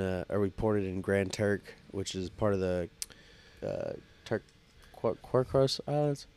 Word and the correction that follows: Caicos: Well, how do you say it? – Turk and Caicos? – Turk and Caicos Islands, yeah.